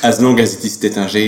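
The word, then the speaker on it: Taittinger: I would say we in a French way, you know, we pronounce it Tétingé. You know, it's very neutral in a neutral way, but some said Tatingé, Tatinger, but the French they said Tétingé.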